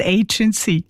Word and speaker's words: Agency: Und wenn ich ja Englisch rede, muss es vielleicht richtig aussprechen. Es heisst Agency.